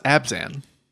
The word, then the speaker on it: Abzan: It's pronounced Abzan. – That's one pronunciation.